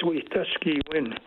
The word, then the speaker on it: Wetaskiwin: As a matter of fact, I was a little bit late tonight because there was a march in the city of Wetaskiwin, the most mispronounced name in Canada. It's Wetaskiwin, and that's a Cree word that means having good relations.